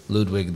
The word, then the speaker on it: Ludwig: And then, not to be confused with Mad King Ludwig, that was Ludwig the First's grandson, I think. Did I say that right, Matt? – Yes.